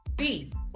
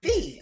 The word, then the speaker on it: Phea: Phea. Phea. – Oh, Chandrika Phea. Okay. – Like I should have charged you a fee for this.